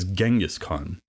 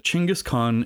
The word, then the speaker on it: Chingiss Khan: You may have heard of him referred to as Genghis Khan, but it's generally agreed that Chingiss Khan is a more accurate pronunciation, so that's how I'll be referring to him henceforth.